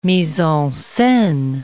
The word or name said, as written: mise-en-scene